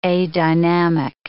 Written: adynamic

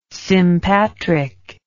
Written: sympatric

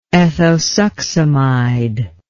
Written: ethosuximide

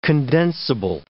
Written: condensible

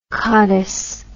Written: cotise

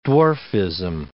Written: dwarfism

